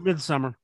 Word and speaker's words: Midsommar: it's pronounced Midsommar.